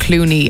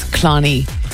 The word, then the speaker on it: Clooney: I must say, at the beginning I accidentally pronounced Clooney as Clonny.